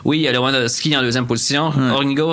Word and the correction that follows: Jorginho: en troisième. C'est comme ça se prononce? Jorginho qui joue pour le Chelsea. – Ouais, Jorginho.